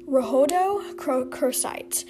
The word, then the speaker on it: rhodochrosite: Okay, and the last crystal, which is also a weird one to pronounce, but the most common way to pronounce it is rhodochrosite. It's spelled O-D-O-C-H-R-O-S-I-T-E.